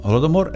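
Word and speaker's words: Holodomor (Голодомор): Holodomor in ucraino si pronuncia così, significa morire di fame. Ecco, Holodomor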